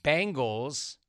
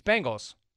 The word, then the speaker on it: Bengals: It's not Bengals. It's not Bengals. It's Bengals. Really, it's a simple word.